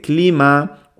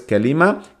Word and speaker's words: كلمة: Luego es verdad que la palabra كلمة, hay gente que dice clima o hay como diferentes maneras de pronunciarla, como ya hemos dicho, en función de la zona.